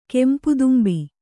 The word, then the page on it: ಕೆಂಪುದುಂಬಿ: kempu dumbi